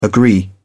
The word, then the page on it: agree: British English